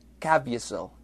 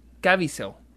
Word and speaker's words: Caviezel: Jim Porque mira, está Bie, Biesel, así que podría ser Jim Caviezel. Caviezel. Jim Caviezel.